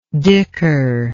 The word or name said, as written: dhikr